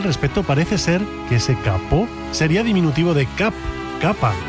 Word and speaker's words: capot: El alemán tomó prestado ese caput en el siglo XVII del francés capot, hoy pronunciado capó concretamente,